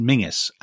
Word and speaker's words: Menzies: And his name, interestingly, so it's obviously a Scottish surname, which Scots will will tell you is pronounced Mingus, and he would have told you that himself, I think, wouldn't he? – he yes, yes, he did.